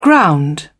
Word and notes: ground: British English

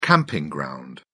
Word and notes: camping ground: British English

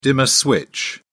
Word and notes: dimmer: British English

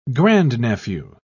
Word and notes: grandnephew: British English

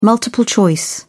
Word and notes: multiple-choice: British English

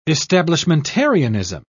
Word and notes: establishmentarianism: British English

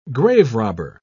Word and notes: graverobber: British English

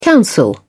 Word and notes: counsel: British English

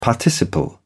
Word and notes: participle: British English

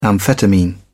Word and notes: amphetamine: British English

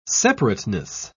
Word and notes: separateness: British English

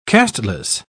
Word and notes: casteless: British English

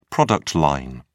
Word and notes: product line: British English

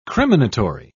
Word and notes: criminatory: British English

